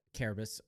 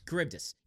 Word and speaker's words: Charybdis: Like, even though Carbus was made from all three of them, there's still Carbus. I never pronounce it right. – Charybdis.